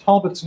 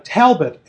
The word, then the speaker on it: Talbot: I did notice while listening today you pronounced Talbot's name, Talbot as an A with an apple, because I'm a Midwesterner, right? And B as in strobe.